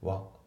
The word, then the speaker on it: were: で、あと一応発音なんだけど、 We were のところの were なんだけど、これは were って発音されてないですね。わぐらいですね。まあ、こういう B 動詞ってやっぱ弱く発音されることが多いんですよね。